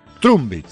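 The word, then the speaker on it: Trumbić: Porque tiene un acento sobre la letra C final, que en croata se pronuncia CH, por eso yo dije Trumbich.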